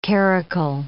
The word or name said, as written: caracul